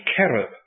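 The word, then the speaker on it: cherub (כרוב): The Hebrew word, as far as I can pronounce it, is cherub, with a K, cherub. And you'll understand that we've merely softened the beginning of it, and we've called it cherub.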